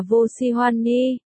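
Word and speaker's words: vô si hoan ni (我喜欢你): Tôi thích cậu trong tiếng Trung được viết là vô si hoan ni, phát âm là vô si hoan ni, chấm chấm, Chu Dịch Phàm rối rắm, cậu không biết Lý Tiêu cũng đang rối rắm giống như cậu.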